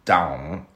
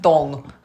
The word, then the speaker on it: Dão: So if you go inland from there, its neighbour is called Dão, which I think is pronounced Dong. – Dong.